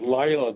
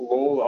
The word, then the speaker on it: Layla: And I think it's pronounced Layla, the song, not Lawler.